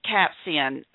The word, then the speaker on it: capsaicin: It's just recently here that we've started hearing on TV advertisement about capsaicin is the actual pronunciation.